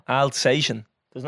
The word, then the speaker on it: Alsatian: Alsatian. There's no T. – You just said Alestation.